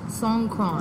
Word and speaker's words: Songkran: Now, we had talked before, and I definitely want you to get into the Songkran. Am I saying that right? – Songkran. – Songkran, yes.